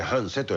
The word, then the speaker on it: 繁盛: なるほど、繁盛と書いて半瀬と読むんですけどね、うん、朝の 10 時から 3 月 4 日の日曜日です。